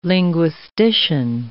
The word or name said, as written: linguistician